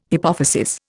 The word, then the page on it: hypophysis: Indian